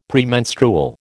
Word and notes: Premenstrual: American English

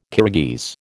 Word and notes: Kirghiz: American English

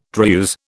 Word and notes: Druse: British English

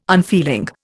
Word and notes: unfeeling: Indian

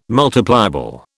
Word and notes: multipliable: British English

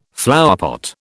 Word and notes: flowerpot: British English

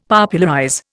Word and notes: Popularize: Indian

